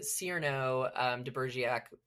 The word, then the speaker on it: Cyrano de Bergerac: Yeah, Calvin Burger is what it was, it's the Cyrano de Bergerac, am I saying that right? – I think so.